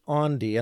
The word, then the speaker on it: Aundi: So it's Andi, and I'll spell it A-U-N-D-I, Andi Kolber with a K, K-O-L-B-E-R.